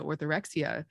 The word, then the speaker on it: orthorexia: And I think that's really important to call attention to orthorexia.